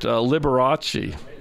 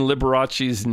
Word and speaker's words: Liberace: It looks a whole lot like Liberace. You know, L-I-B-E-R-A-C-E. How does a seven-year-old know that you're supposed to throw an H in there to pronounce it, you know?